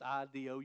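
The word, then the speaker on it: I do (ἰδού): I can rem- remember that Greek I do because it's I D O U. I do. That's the way I pronounce it anyway.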